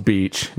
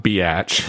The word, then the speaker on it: Beatch: And they end up changing their name at that time to Beatnik Beach, and it's spelled B-E-A-T-N-I-K. Beach is spelled B-E-A-T-C-H. And it's pronounced beach, not biatch.